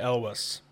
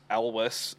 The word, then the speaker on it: Elwes: It's Cary Elwes. – Elwes.